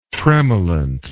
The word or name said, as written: tremolant